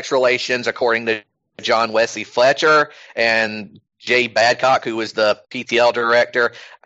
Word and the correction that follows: Babcock: I just realized what that last name is. God damn it. it's actually pronounced Babcock, but yeah.